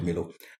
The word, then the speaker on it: Milo: You know, growing up, I thought things like, some call it Milo, some call it Milo. I call it Milo. I thought it was Ghanaian. – Yeah, that's the right way to say it.